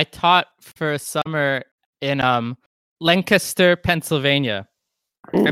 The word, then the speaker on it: Lancaster: And that's how you say it, Lancaster. It's spelled, it looks like Lancaster, right? Exactly how you would think Lancaster would be pronounced, but it's Lancaster, Pennsylvania. And if you say it wrong, the amount of mocking and ridicule that's directed your way is it's really undeserved.